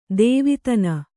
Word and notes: ಧವಾದಿನಿ: kannada

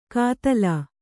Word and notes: ಕಾತಲಾ: kātalā

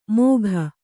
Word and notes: ಮೋಘ: Kannada

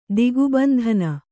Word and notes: ದಿಗ್ಬಂಧನ: digbandhana

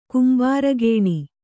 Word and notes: ಕೆಂಬರುಗಣ್ಣು: kembarugaṇṇu

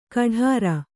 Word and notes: ಕಢಾರ: kaḍhāra

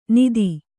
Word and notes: ನಿಧಿ: nidhi

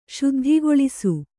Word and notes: ಶುದ್ಧಿಗೊಳಿಸು: śuddhigoḷisu

